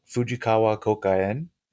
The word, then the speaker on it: Fujikawa Kouka-en: Well, would you tell me a little bit about Fujikawa Kokaen? – Did I say that correctly? – Yes, you did. Perfect.